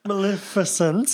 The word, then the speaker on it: Maleficent: Maleficent 2.